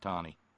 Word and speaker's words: Taney: Yeah, it's T-A-N-E-Y, but it's pronounced Tawny.